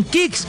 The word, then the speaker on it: Kixx: Así como los campeones se preparan para ganar, protege tu moto con Kicks, K-I-X-X. Aceites sintéticos y semisintéticos para un máximo rendimiento. Síguenos ya mismo en Instagram como @aceite_kicks. Repito, @aceite_kicks. El Kicks se escribe K-I-X-X.